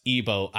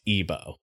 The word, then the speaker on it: Igbo: I mean, I don't know this language, but I do think the G is generally silent. From my understanding, it is pronounced Igbo either way.